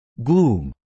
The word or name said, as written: gloom